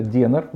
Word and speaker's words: денар: А в Македонии это денар, ударение на Е, mm в отличие от динара.